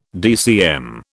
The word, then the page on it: DCM: British English